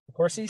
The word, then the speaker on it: DeCourcy: And so that's my point too, where back to Michael, was it DeCourcy? Is that how you say his name? – DeCourcy.